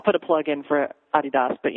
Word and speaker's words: Adidas: I think the coolest thing is if you watch those commercials, the Adidas commercials, the Adidas commercials, as we pronounce it here in the States, for Star Wars, there's a great cantina scene with Beckham and Snoop Dogg and Windows from Fanboys.